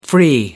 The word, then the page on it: free: American English